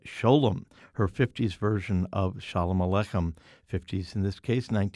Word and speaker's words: Sholem: In fact, even on the single, it says S-H-O-L-E-M, pronounced Shalom, Eartha Kitt.